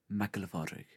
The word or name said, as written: MacGiollaPhádraig